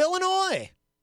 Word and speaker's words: Illinois: Crystal joins us from the great state of Illinois. And no, you do not pronounce the S.